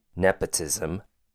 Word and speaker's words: nepotism: Next word, nepotism. Four syllables. Ne has a short E sound, P has a short U sound, T has a short I sound, and SIM. Sounds like SIM as in SIM card. Nepotism.